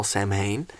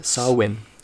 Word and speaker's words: Samhain: It's widely believed that many Halloween traditions originated from ancient Celtic harvest festivals, particularly the Gaelic festival Samhain. – It's pronounced Samhain.